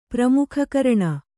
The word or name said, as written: ಪ್ರಮುಖಕರಣ